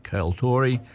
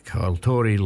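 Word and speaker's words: Ceoltóirí: it's C-E-O-L-T-O-I-R-I, Kaltori, L-A-I-G-H-E-A-N. Kaltori Lion, or possibly Lian, L-A-I-G-H-E-A-N.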